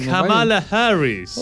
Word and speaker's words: Kamala Harris: Ahora hay toda una campaña que tiene que ver con Kamala Harris, que es la. – Qué buena pronunciación. – Kamala Harris.